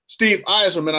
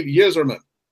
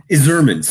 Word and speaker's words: Yzerman: You notice I did pronounce his name Steve Yzerman. I didn't go Bill Clinton uh, like a 97 and say Steve Yizerman because I respect the man. I learned his name properly. Yzerman.